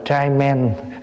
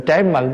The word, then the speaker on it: trái mận: đi về Việt Nam mà thấy người ta để trái mận đọc là trai men. Hay á. Tại vì trai là cũng là men, mà men là trai, rồi trái mận thì đọc là trai men.